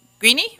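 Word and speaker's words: Greaney: I pronounced it right, Greeny.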